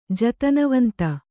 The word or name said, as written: ಜತನವಂತ